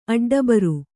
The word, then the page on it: ಅಡ್ಡಬರ್: aḍḍabar